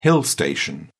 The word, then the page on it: hill station: British English